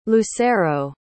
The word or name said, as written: lucero